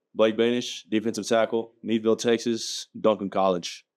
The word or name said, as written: Blake Boenisch